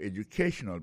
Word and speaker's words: educational: We'll be talking about your educational background. Alors, accent tonique là, que tu peux et plus là, il est tombé sous troisième syllabe là.